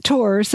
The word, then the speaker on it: tours: Not related to any specific episode or episodes, I noticed that the Francophone characters, like Matthew and Gerbert, pronounce tours the French way. Whatever that might be. I'm not the person to ask. With the silent... – Our French is abysmal. – With the silent S at the end.